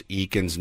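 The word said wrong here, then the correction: Eakins: name wrong for the entirety of our career. I think it's Aikens. – Aikens.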